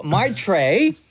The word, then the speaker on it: Maitreyi: So Maitreyi, did I say that right, Maury? – You did.